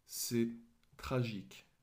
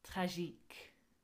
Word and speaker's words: tragique: And then it is tragic is c'est tragique. – Which is spelled more or less the same, except to replace the C on the end, the French put Q-U-E, tragique.